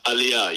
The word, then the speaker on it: Aliyah: Given your unique perspective, having grown up much of your life inside the United Kingdom, having made, is it Aliyah? Am I saying that correctly when you moved to Israel? – Aliyah, yes. Aliyah.